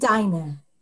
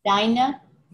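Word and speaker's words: dayne: And deine is the plural. It has that ein, that e sound at the end, because it's presumed to be plural, that it's you, whoever your people are.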